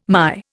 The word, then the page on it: MY: Indian